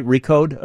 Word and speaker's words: Recode: Peter Kafka is a senior correspondent at, am I saying it right, Recode? – You got it. – R-E-C-O-D-E, Recode, host of Recode Media, which is a weekly podcast.